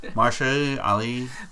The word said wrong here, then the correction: Mahershala Ali: Am I pronouncing it right or not? – Mahershala Ali. – Yes, okay. – No, ma'am. You guys don't watch Nick Cage? Oh, my God. Mahershala Ali.